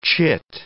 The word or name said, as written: chit